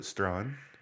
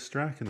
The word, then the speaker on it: Strachan: Is there a silent J in there? S T R A C H A N. So everyone goes, "Oh, Strachan." Oh, Strachan. but it says Strachan on the sign. Yeah, I know it says Strachan on the sign, but it's pronounced Strachan.